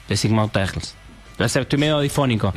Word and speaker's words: Les Immortels: Les Immortels, no sé si se pronuncia así en francés, pero... – Les Immortels.